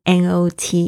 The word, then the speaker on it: knot: 嗯，K N 开头的字呢，通常这个 K 它是不能发音的，Not 它听起来就是跟 N O T 一样的发音。